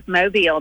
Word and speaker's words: Mobile: And by the way, is Mobile, is that the correct pronunciation? – It is Mobile. That's right.